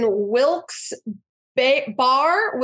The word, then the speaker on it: Wilkes-Barre: Friday Night Smackdown is in Wilkes-Barre, Wilkes-Barre, Pennsylvania. I've heard it pronounced both ways. – It's B-A-R-R-E, and they say Barry sometimes, huh? – Sometimes.